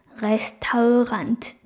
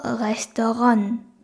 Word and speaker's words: Restaurant: なので、そのまま綴りをドイツ語発音で読んでみると、レスタウラントになるんですけど、レスタウランとちょっとね、通じる通じない微妙なラインかなと思います。伝わる発音で読んでみると、レストランです。